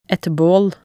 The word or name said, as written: et baal